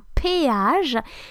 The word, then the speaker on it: péage: it's called un péage. It's P-E-A-Q-A-G-E, un péage. And I just found that even though it's spelled different, it sounds a little bit like payage, where you have to pay.